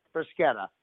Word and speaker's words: bruschetta: Yeah, bruschetta. – The actual pronunciation is bruschetta. It's just, bruschetta. That CH is a hard, like K sound, bruschetta.